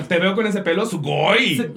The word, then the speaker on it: sugoi: Me gusta mucho, aparte me gusta mucho la palabra sugoi, que, siento que la tienes que pronunciar con la pronunciación japonesa como de sugoi.